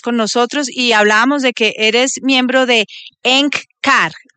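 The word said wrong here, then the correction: Eckankar: Se pronuncia ECANCAR, S-K-A-N-K-A-R, significa colaborador con Dios. – Perfecto, ECANCAR.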